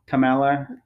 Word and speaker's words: Kamala: I think it's Kamala. – Kamala.